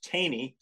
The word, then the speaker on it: Thoene: Well, T-H-O-E-N-E, pronounced Taney so that it rhymes with rainy. But it's probably only pronounced that way at sight in one little corner of Switzerland. That's where it's from.